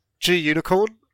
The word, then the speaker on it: Gunicorn: I prefer to say G Unicorn. – That's how I say it. – Yeah, some people say Gunicorn, but I don't like using the word gun. So G Unicorn.